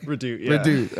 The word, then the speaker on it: Redoute: Redoute.